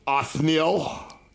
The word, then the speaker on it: Othniel: Othniel.